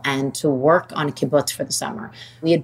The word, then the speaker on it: kibbutz: If you don't mind, will you tell us a little bit about living in the kibbutz? – Oh, sure. – Am I saying that correctly? Kibbutz. – Yes, you are. Beautifully. Kibbutz.